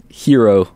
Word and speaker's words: Jiro: Going with Hero Dreams of Sushi. And it's spelled J-I-R-O.